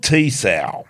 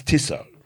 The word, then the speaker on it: Tissot: It is not Tissot. – No. – It's Tissot. – Tissot. Oh, the watches, yes. – Tissot. Yeah. – Tissot. – I don't think Matt can afford a Tissot watch, can he, with what they pay him? – No, they don't pay him.